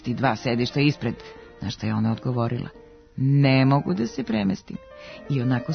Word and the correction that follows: ne mogu: Kaže se "ne mogu", a ne "ne mogu". Do ove greške dolazi zbog analogije sa akcentovanim oblicima ostalih pet oblika sadašnjeg vremena glagola moći, u kojima je naglasak na negaciji. Tako se u prvom licu jednine, umjesto pravilnog oblika sa kratkouzlaznim akcentom na reči "mogu", pojavio oblik sa akcentom na "ne", pa se umjesto pravilnog "ne mogu" sve češće čuje nepravilan oblik "ne mogu".